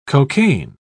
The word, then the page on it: cocain: British English